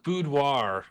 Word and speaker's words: boudoir: So it's pronounced boudoir. – Boudoir. – Pretty simple, but if you haven't heard it before, you sort of look at the words, it's kind of weird because it's a French word.